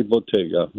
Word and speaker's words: Bottega: Is it Bottega? – Stefani Bottega, right. – Stefani Bottega, B-O-T-T-E-G-A, Italiana.